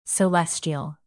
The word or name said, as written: celestial